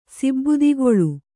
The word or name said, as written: ಸಿಬ್ಬಿದಿಗೊಳ್